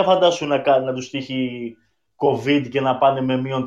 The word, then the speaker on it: COVID: Να σου πω, ε, το COVID, γιατί το, έτσι τονίζεται στο Ιωτά, COVID.